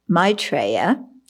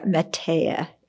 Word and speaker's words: Metteya: So according to the Pali tradition, Maitreya, or Metteya is how it's said in Pali,